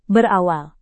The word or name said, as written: berawal